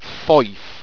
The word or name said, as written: foife